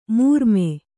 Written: ಮರುಮೈ

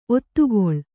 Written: ಒತ್ತೆಗೊಳ್ಳು

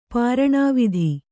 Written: ಪ್ರಾಣಾವಧಿ

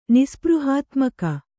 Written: ನಿಸ್ಪೃಹಾತ್ಮಕ

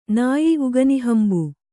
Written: ನಯ

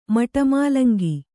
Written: ಮಟಮೂಲಂಗಿ

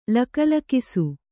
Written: ಲಕಲಕಿಸು

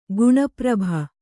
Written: ಗುಣಪ್ರಭ

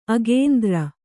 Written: ಅಗೇಂದ್ರ

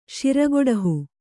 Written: ಶಿರಗೊಡಹು

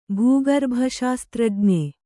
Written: ಭೂಗರ್ಭಶಾಸ್ತ್ರಜ್ಞ

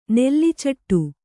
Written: ನೆಲ್ಲಿಚೆಟ್ಟು

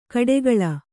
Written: ಕೊಟ್ಟೆಗೋಳಿ

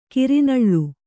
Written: ಕಿರಿನಳ್ಳು

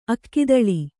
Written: ಅಕ್ಕಿದಳಿ

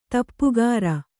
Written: ತಪಕೀರ

